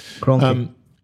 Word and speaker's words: Kroenke: Arsenal have turned on Kroenke, whatever his name is. Yeah. I always forget how you pronounce it. – Kroenke.